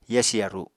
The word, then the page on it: yasi yaru: apatani